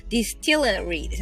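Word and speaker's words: distillery: ディスティルのここは L なので、その後に ry をつけなきゃいけないっていうあの日本人泣かせの発音並びをしているんですけれども、ディスティルリーですね。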